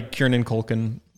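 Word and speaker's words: Kieran Culkin: And it's played by Kiernan Culkin.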